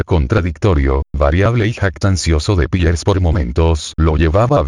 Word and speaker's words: Peirce: En inglés Peirce se pronuncia Peirce.